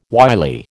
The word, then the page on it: wily: American English